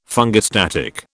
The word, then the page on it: fungistatic: British English